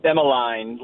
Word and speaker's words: Emmeline: You have a lovely life, three kids, Adlin, Isaac, and Emmeline. I always say your last daughter's name wrong. Pronounce that for me, would you? – Emmeline, like Caroline.